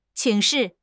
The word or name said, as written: 请示